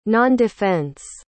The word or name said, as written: nondefense